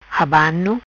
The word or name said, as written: Abano